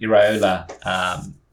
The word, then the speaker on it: Iraola: Just going back to Bournemouth, a new manager has come in, of course, Andoni Iraola.